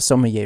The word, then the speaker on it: sommelier: This is a very, very French word, and it's not sounding even remotely the way that it is spelled. It is spelled S-O-M-M. You still with me? S-O-M-M-E-L-I-E-R. Sommelier.